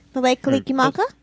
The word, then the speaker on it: Mele Kalikimaka: Mele kalikamaka? – Mele kalikilmaka. – Bless you. – Gesundheit. – He wins. – You actually said it right. – Mele kalikimaka? – Damn. Okay. – Merry Christmas. – Mele kalikimaka? – Yeah. Mele kalikimaka.